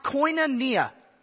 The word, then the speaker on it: koinonia: This is New Testament Greek and it's pronounced koinonia. Koinonia. Everyone say koinonia.